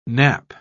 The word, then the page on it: nappe: British English